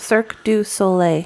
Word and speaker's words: Cirque du Soleil: Like a Circus Olay kind of thing. – Oh, Cirque du Soleil? – Yeah, Circus, how do you say it? – Cirque du Soleil.